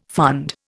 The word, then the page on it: fund: Indian